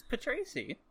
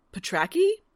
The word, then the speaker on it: Patracy: It's Patracy. – Tony Robinson, who reads the audiobook, pronounces as Patraki. Like, he says it as an actual name.